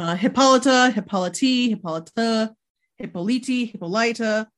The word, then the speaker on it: Hippolyta: But I guess it would be Hippolyta if her nickname is Lyta. – Yeah. – Then it makes sense that her full name would be Hippolyta with the emphasis on the end, and that's how you get the nickname.